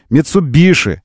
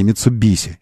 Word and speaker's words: Mitsubishi: Mitsubishi, а не Mitsubishi. Это не я придумал. Это наши японские друзья, руководители бренда Mitsubishi в России настаивают.